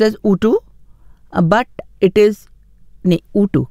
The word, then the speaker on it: UTO: अच्छा कोड मैंने शेयर कर दिया, चलो इट्स प्रोनाउंसड ऊ टू सॉरी माई बैड 3631 my apologies, इसलिए मैंने पहले ही कह दिया था कि शायद मैं गलत प्रोनाउंस कर रही हूँ. ऊ टू, आई होप आई सेड इट right, बट spelling इसकी UTO है ना, तो मैं थोड़ा सा कन्फ्यूज हो गई थी, UTO है मगर हम ऊटू बोलेंगे तो लोग इसको WOTO लिखेंगे, लेकिन कन्फ्यूजन मुझे हो गया था.